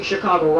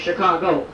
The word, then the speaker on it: Chicago: Now, the settlers that came here were not pronouncing Chicago right. They were saying Chicago. In Potawatomi, it's actually pronounced Chicago.